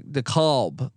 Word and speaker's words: DeKalb: And is it DeKalb? How do I pronounce that, by the way? I always get some people, DeKalb. Okay. DeKalb. Okay.